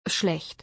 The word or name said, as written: Schlecht.